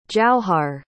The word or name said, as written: jauhar